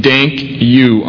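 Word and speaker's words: dank u: I remember the trash cans say dank you on them. Like, thank you, that's how you say it in Dutch.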